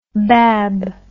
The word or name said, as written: bab